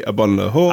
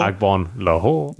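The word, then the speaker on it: Agbonlahor: It used to be Abon-Lahore. – Agbon-Lahore.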